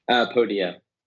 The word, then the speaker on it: Podia: Podia. – Podia. – So it's the plural form of podium. So Podia.